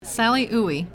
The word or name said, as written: Sally Oey